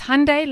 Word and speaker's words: Hyundai: What is it then? – It's Hyundai like Sunday. – Hyundai.